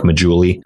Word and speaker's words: Maggiulli: If you just search my name, Nick Maggiulli, you're not gonna be able to spell that.